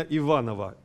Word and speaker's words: Иванов: А кто мне скажет, самая известная картина Иванова, ударение именно так, Иванов.